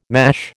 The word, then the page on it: mash: American English